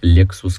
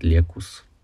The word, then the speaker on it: Lexus: Может, ваши друзья говорят вместо Lexus, говорят Лекус или, или что-нибудь такое смешное, забавное.